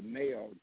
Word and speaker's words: MAOA: And the thing that I'm, I'm working with and looking at is have you ever heard of the Mayo gene? it's spelled M A O A.